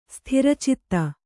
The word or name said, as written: ಸ್ಥಿರಚಿತ್ತೆ